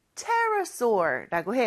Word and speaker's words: pterosaur: Pterosaur라고 해요. 근데 왜 P 발음을 안 할까요? P는 무음입니다. 그래서 스펠링은 P로 시작하지만 발음은 Pterosaur라고 해요.